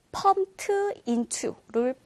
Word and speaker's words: pumped: 펌프라는 동사에 ed가 붙어서 펌피드처럼 생겼지만, 실은 이 부분의 올바른 발음은요, pumped로 발음이 되고, 끝에 t 사운드로 되고, 뒤에 모음, i 소리가 있기 때문에, pumped into를 빠르게 발음하다 보면, pumped into.